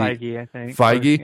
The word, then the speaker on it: Feige: It's Kevin Feige or something like that. F-E-I-G. – Feige.